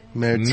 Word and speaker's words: Märzen: How do you properly say, is it Märzen? – Märzen. – Märzen. You have to say it like, you have to say it with a, like, singing like that? – It's a sing song thing, you know.